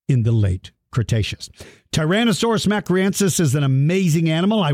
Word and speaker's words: mcraeensis: Its last name is spelled MCRAEENSIS. Tyrannosaurus macriensis. For all of you that are listening,